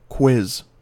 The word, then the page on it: quiz: American English